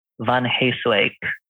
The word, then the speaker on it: van Heeswijck: So my full name, so I'm Julian Christoph Romero van Heeswijck.